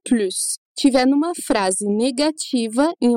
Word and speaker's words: plus: Uma explicação bem simples sobre o assunto, que pode te ajudar, é que quando plus significa mais, a gente vai pronunciar o S, então plus, como na nossa frase.